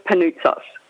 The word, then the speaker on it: Panoutsos: And yeah, unfortunately, it is a bit of a mouthful, but it's pronounced Panoutsos.